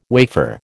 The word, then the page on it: wafer: American English